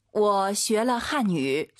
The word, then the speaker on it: 我学了汉语: 我学了汉语. – 삼성 이성 아아 들려야죠. 我学了. 뚝 떨어져야 돼요.